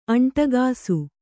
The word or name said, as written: ಅಂಟಗಾಸು